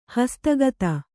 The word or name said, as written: ಹಸ್ತಗತ